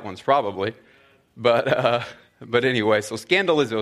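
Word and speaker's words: scandalizo (σκανδαλίζω): And that word offended in the Greek, and I won't hang out on the Greek very long here, but it's actually pronounced scandalizo, and obviously has the word scandal in it.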